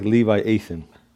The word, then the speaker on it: Leviathan: Yeah, how do you pronounce it? I'm waiting for you to pronounce that, though. We're not going to let you off the hook here. – Leviathan.